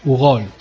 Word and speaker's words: Hugault: Mm, es Romain Hugault, Hugault se pronuncia, es, francés.